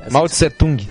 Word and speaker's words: Mao Tse-tung: E Mao Tse-tung, é isso? – Mao Tse-tung.